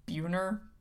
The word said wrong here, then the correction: Beuhner: Sure. – Which sounds kind of like Bueller. Ferris Bueller. It's Beener.